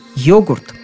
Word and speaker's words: йогурт: входило это слово сразу с ударением на первом слоге – «йогурт».